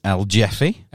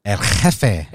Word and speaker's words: El Jefe: Look, if I was uncultured, yep. – I would pronounce this El Jeffy. – El Jeffy. – But this, of course, is El Jefe.